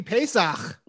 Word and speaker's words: Pesach: Matzah season. Happy Pesach. – Coming. Yeah. I don't know what that means, but that was a very nice pronunciation.